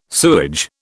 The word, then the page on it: sewage: British English